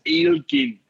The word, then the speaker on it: Elgin: But up in Elgin, which the Gaelic pronunciation, of course, is Eilgin. Eilgin.